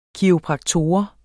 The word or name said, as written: kiropraktor